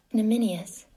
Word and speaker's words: ignominious: Ignominious. – Ignominious is how you say it.